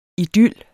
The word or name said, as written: idyl